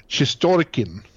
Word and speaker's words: Shestorkin: så uttalar Shestorkin. – Ja, precis. På engelska stavningen så blir det ju Shestorkin, men det ska ju vara Shestorkin på något vis.